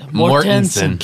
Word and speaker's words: Mortensen: Mortensen. – Kenneth Longo. – I don't hear the difference. – Mortensen. – What am I saying? – Mortensen. – I think you said Mortensen. – Several times. – Mortensen.